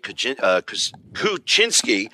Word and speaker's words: Kuczynski: A little bit of a heartbreaker early in the season with the overtime, but we've got to talk about Jake Kuczynski. And I have to say that right because I said Kuczynski during Bellarmine, and we had a couple of fans come over and correct me on that.